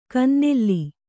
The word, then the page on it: ಕಿನ್ನೆಲ: kannada